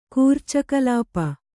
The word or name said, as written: ಕೂರ್ಚಕಲಾಪ